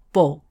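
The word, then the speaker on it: bók: 冰岛文“ 书” 这个字的拼法是 B。 o 上面加一点 k，念成 book。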